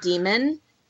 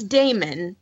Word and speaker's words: Damon: First of all, in the audiobook, the person mispronounces his name every time she says it and calls him Demon. It's Damon. That's how you're supposed to pronounce that.